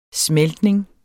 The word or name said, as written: smeltning